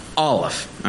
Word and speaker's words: Aleph: By the way, do you know how to pronounce the letter Aleph in Hebrew? Do you want me to do it for you? Are you ready? Did you hear it? Because you don't pronounce the letter Aleph. The first letter of the Hebrew, you don't pronounce.